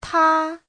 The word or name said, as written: ta1